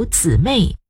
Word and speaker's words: 姊妹: 以前我听一些老牧师在教会称呼女信徒姊妹，我还心里嘀咕牧师的发音错了，后来才知道牧师的发音没有错，因为既有姐妹也有姊妹，两个词的意思一样，子就是姐，说文写道子女兄也，也就是女性兄长的意思，比自己先出生的同胞女子。